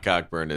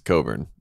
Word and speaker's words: Cockburn: It's not Cockburn. It's Coburn. I'm like, well, who has a silent C and K in their name? – Did you see he punch the ref?